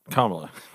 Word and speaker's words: Kamala: Is it Kamala or Kamala? Kamala. Okay. – Yeah, that's a good one. I say Kamala or Kamala.